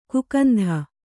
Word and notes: ಕಾಕೇಂದು: kannada

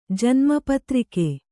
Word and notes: ಪೌತ್ರಕ: pautraka

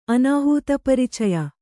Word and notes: ಅನಾಹೂತಪರಿಚಯ: Kannada

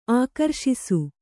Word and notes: ಆಕ್ರೋಶಿಸು: ākrōśisu

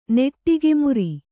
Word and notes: ನೆಟಿಗೆ ಮುರಿ: neṭige muri

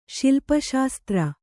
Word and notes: ಶಿಲ್ಪಶಾಸ್ತ್ರ: śilpa śastra

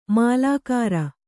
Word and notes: ಮಾಲಗಾರ: mālagāra

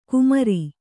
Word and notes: ಕುಮಾರಿ: kumāri

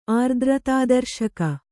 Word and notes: ಆರ್ದ್ರತಾದರ್ಶಕ: kannada

